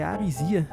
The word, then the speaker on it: Arisia: O podcast é Arisia. Se escreve assim: A-R-I-S-I-A. Eu ia dizer Z, mas não é com Z, é com S. A-R-I-S-I-A.